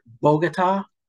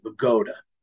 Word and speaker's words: Bogota: Now, if you see this on a map, for any of you outside of the tri-state area, it looks like Bogota, just like Colombia, but no, in Bergen County, we pronounce it Bogota.